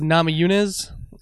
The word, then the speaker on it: Namajunas: And Rose Namajunas.